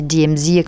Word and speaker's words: DMZ: il me dit ben, là-bas on dit DMZ comme à l'américaine, la dématérialisation militaire, démilitarisée zone.